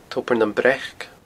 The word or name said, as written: tobar nam breac